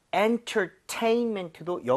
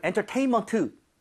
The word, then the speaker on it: entertainment: 자, entertainment도 여기 강세를 줘서 여러분 entertainment 이게 아니라 네? – 제가 두 번 들려드릴게요.